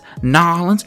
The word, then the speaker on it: New Orleans: My cab driver, he said New Orleans. Welcome to New Orleans. That's all he said. N-A-W-L-E-N-S. That's not how it's spelled. He says New Orleans.